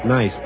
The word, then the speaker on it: Nice: The city's called Nice. It's spelled the same way as the word nice, but they pronounce it Nice over here.